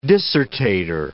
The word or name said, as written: dissertator